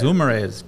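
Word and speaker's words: Guimarães: How do we say it? Gomes? Gilmeres? – You're asking me how to pronounce it, something? – Is it Gilmeres? – Yes, it is. Gilmeres.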